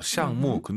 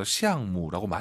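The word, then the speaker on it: 项目: 프로젝트 발음 둘 다 사성이에요. 시앙무. 근데 원어민 선생님들 발음 들어보시면 무를 약간 경성으로 발음해서 시앙무라고 많이 하더라고요.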